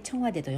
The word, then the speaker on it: 青瓦台: えチョンワデ青瓦台青い瓦の台と書いてチョンワデと読む